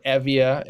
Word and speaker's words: Evia: And Eretria is on the island of Euboea, or Evia, as it would be pronounced, today, which is north.